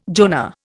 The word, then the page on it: Jonah: Indian